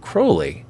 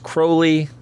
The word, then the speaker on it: Crowley: Also, just a quick note, I believe his name is pronounced Crowley, as Robert Anton Wilson constantly reminds me. It's supposed to rhyme with holy.